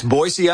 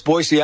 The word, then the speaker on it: Boise: People always say it with a Z, right? – That's right. It's Boise, Idaho. The locals know it's Boise, Idaho.